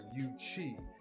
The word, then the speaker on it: Yuezhi: the UZ, pronounced Y-U-E-Z-H-I or Yuchi, which translates to Western Barbarian, aka Conan the Barbarian.